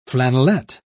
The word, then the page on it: flannelet: British English